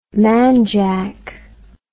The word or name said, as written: manjak